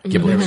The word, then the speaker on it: Ghibli: It's interesting, and So the Ghibli films, am I saying that right now? – Yeah, Ghibli, Studio Ghibli, yeah.